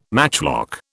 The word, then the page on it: matchlock: British English